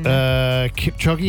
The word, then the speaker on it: Kipchoge: Kipchoge, is that how I say his name? – Yeah.